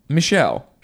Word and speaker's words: Michel: I don't know how to pronounce the name of the second candidate, whose name looks like it could be Michael or Michelle. – I think it's Michelle. – M-I-C-H-E-L. Michelle.